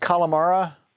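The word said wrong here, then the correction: Kalorama: Neighborhood? – Calorama neighborhood. – Calorama, thank you.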